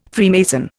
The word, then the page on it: Freemason: Indian